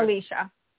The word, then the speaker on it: Alicia: It's Alyssa? Is that right? Alicia.